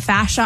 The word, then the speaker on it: fascia: It was a lymphatic and a, I'm going to pronounce this wrong, fascia. – Fascia.